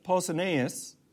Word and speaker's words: Pausanias: The next one is Pausanias. And that's spelled P like Paul's, P-A-U-S-A-N-I-A-S.